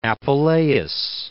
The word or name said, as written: Apuleius